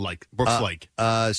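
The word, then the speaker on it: Laich: It's Brooks Laish.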